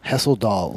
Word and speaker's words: Hesseldahl: Hesseldahl. Like, yep. Like, what a doll. – Like lentils. – Yeah. – Okay. – I am a doll. – Dane Hesseldahl, the reigning king of Jai Tai.